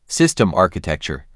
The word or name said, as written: System architecture